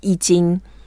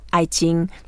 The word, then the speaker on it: I Ching: And sometimes when we just say, oh, this is Tai Chi, that's actually from the ancient form like I Ching, and Western people they call I Ching, but it's actually I Ching.